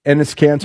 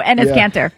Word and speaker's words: Enes Kanter: Enes Kanter. – There we go. Enes Kanter.